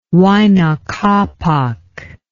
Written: Huayna Capac

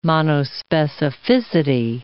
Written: monospecificity

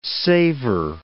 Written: savor

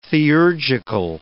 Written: theurgical

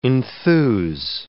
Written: enthuse

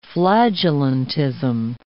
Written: flagellantism

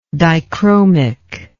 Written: dichromic

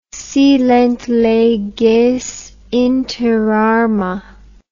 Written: silent leges inter arma

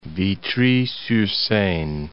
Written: Vitry-sur-Seine

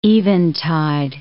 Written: eventide

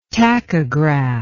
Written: tachograph

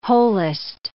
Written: holist

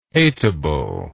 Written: hateable

